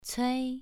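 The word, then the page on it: cui: tone 2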